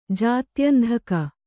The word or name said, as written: ಜಾತ್ಯಂಧಕ